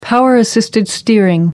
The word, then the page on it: power-assisted steering: American English